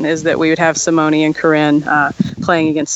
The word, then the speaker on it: Simone: All right, and and uh, and Simone, that is the way to pronounce her name because it's kind of like Casa Grande. I got half the people saying Simone, and then the other half saying Simone, but she prefers Simone.